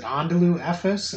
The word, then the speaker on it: Anadolu Efes: Is it Anadolu Efes? Is that how you say it? – Yep, that's good.